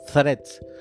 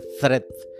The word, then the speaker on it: Threads: De hecho, la pronunciación correcta como es Threads. Como si fueras a decir una Z española, dice Threads.